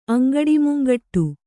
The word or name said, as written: ಅಂಗಡಿಮುಂಗಟ್ಟು